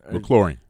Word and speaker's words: McLaurin: A couple guys who have potential, like Deebo Samuel and Terry McLaughlin. – McLaurin. McLaurin. Yeah, uh-huh. I don't know why I said that wrong.